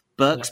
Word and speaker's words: Birx: And then there was a great piece by Jeffrey Tucker about Deborah Birx. Is that how you pronounce her name? Birx, yeah. B-I-R-X.